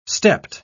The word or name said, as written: stepped